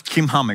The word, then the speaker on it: Chimham: It's pronounced as Chimham, actually, in Hebrew.